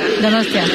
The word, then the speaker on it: Donostia: How do I say it? Donostia. – Donostia. – Donostia.